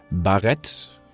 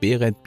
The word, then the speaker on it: Barett: Und, und, ich möchte an äh, der Stelle, liebe Hörer, nochmal nachreichen, dass der Kollege Nietzsche mit seinem Barett als äh, äh Bezeichnung der Mütze seines Weinhändlers ziemlich gut lag. Also Baret, Beret, Biret, da gibt es verschiedene Aussprachevarianten, aber das trifft das relativ genau.